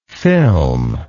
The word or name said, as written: filmlike